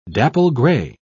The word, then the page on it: dapple-gray: British English